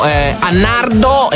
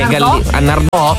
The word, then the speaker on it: Nardò: Nell'Alto Salento, Annardo e Annardò, sì, c'è l'accento, dice.